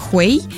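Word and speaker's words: hui: Это слог «хуэй». И просто если его читать чисто по буквам, да, возможно его прочитать как матерное слово. Но на самом деле, произнося его, ты его произносишь как «хуэй».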